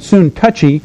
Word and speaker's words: Syntyche: He says, I urge Euodia and I urge Syntyche, some pronounce it soon touchy, to live in harmony in the Lord.